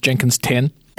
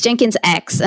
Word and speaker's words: Jenkins X: You sure it's not pronounced Jenkins 10? – No, it's Jenkins X, and it comes complete with, you have to do the hand gesture where you make an X.